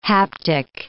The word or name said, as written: haptic